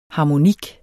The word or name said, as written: harmonik